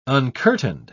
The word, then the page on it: uncurtained: British English